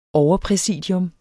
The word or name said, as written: overpræsidium